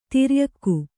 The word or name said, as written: ಧೌರೇಯಕ